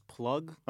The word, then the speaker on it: plugg: I would just categorize this sound as plug. – Uh huh. – Like, it's spelled like P-L-U-G-G.